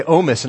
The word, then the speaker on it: Ohmes: Now, my last name is Ohmes. It gets mistaken for Ohms, so I'll give you one free pass if you say my name wrong, then I'll hold it against you. No, I'm kidding. But we say Ohmes, and I'm really proud of the Ohmes name.